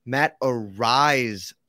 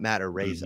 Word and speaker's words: Araiza: It's Matt Araiza, not Matt Araiza.